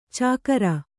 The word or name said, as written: ಚಾಕರಿ